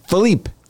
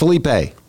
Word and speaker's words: Philippe: We have one active tropical system, and I've been saying it wrong for a while. We finally looked up what it actually should be. It's Philippe. – Philippe. – Not Felipe.